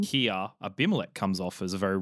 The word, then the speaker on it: Abimelech: The other thing I noticed when reading this was the contrast between the two, Abraham and Abimelech.